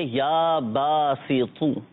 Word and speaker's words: یا باسط: یا باسطو, اچھا یہاں پر ایک چیز قابل غور ہے, اب تو اور تا دونوں میں فرق کرنا ہے. یا باسطو, اب یہاں پر تو ہے. اب ہوتا ہے بعضوں کا جن کے تلفظ درست نہیں ہوتے, تو کی جگہ تا پڑھ لیتے ہیں, یا باسطو یا باسطو یا باسطو, یہ غلط ہو جائے. پڑھیں گے یا باسطو, تو حروف مستعلیہ میں سے ہے اور اس کو پر پڑھنا ہے, موٹا کر کے پڑھنا ہے.